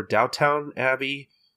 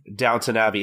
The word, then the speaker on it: Downton Abbey: Is it Downton Abbey or Downtown Abbey? – Downton Abbey.